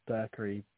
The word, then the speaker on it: Berechree: B-E-A-U. – B-E-A-U. And surname? – Berkery. B-E-R-E-C-H-R-E-E. – C-H-R-E-E.